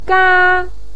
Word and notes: ga: 1

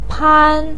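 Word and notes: pan1: tone 1

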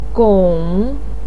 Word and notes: gong3: pinyin

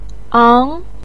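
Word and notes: ang2: tone 2

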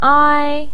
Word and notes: ai: ai1

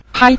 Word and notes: pai: pai5

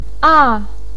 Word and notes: a: a4

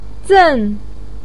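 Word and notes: zen: tone 4

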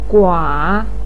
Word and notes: gua: 3

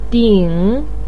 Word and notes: ding3: pinyin

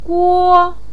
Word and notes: guo: 1

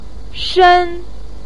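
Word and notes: shen: tone 4

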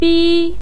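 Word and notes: bi1: tone 1

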